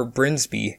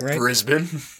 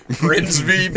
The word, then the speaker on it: Brisbane: Brisbane. I said that right? – Brisbane. Brisbane. – Brisbane, sorry.